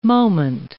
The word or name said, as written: moment